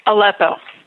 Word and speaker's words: Aleppo: Aleppo.